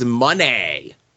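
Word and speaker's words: Moné: Mercedes Money. Even though it's spelled Monet and has the accent symbol over it, her theme song and she says it money. So that's how I'm going to say it.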